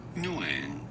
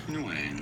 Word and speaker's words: Nguyen: I thought you were talking about N-G-U-Y-E-N, which is Nguyen.